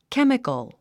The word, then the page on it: chemical: kémikəl; ケミコォ